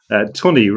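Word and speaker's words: Tunney: Tooney rhymes with money, funny.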